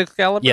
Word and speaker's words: Excalibur: I want to make sure I'm getting his name right. Is it Excalibur? – Yes.